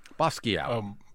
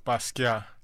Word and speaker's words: Basquiat: Basquiat? Am I saying that right? Basquiat. – Basquiat.